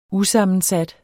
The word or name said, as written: usammensat